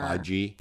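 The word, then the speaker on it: Fage: It's F-A-G-E, Faye. – Right, but it says the container. Faye. Pronounce it Faye.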